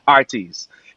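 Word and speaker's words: Heartease: And Jamaicans, for some reason, don't pronounce the H if it starts off with a word. So it's H-E-A-R-T-E-A-S-E, but the, you know, Jamaican way is Artes, you know?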